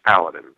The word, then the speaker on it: Paladins: It's Paladins.